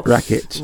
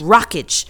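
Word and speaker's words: Rakić: My concern is that I know that Alexander Rakic, or however you say his name, I'm so terrible. – Rakic. – Rakic.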